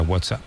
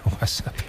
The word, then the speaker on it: WhatsApp: Tú, WhatsApp. – WhatsApp. – No, peor aún, con acento en la primera.